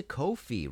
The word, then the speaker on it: Ko-fi: If you like this show and want to support future episodes, please go to Ko-fi, rhymes with, coffee, rhymes with no fee.